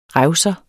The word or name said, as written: revser